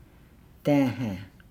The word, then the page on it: daahaa: Nukuoro